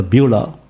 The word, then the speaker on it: Beulah: 那个希伯来字读成不乌拉，大概应该这样读的不乌拉，而英文把它写成 BEULAH，英文的读法就读作 Beulah。从此以后有很多诗歌都用直接用 Beulah 来讲这一件事。我们唱过好些诗歌，有的诗歌根本歌名就叫做 Beulah，像宣道诗 237首，我们中文把它翻译作福地。